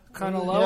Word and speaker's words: Kanaloa: The SS Kanaloa? – Kanaloa? Yeah. Sounds right.